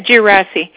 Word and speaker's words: Gersi: Do you have like an email address for Gersi? Am I saying that right? Gersi? – Yeah, Gersi.